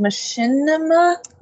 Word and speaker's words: Machinima: Speaking of companies and possibly getting a little bit of a slap, the FTC and Machinima? – You said it right the first time.